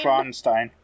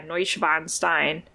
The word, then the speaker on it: Neuschwanstein: And I cannot pronounce this name at all, but I think it might be the Neuschwanstein. – Neuschwanstein. – Oh yeah, Neuschwanstein.